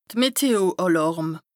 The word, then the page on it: d' Meteo-Àlàrm: Bas-Rhin; Strasbourg